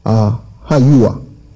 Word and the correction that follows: Yahuwah: Yahuwah, excuse me. Yahuwah.